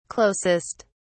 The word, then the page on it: closest: British English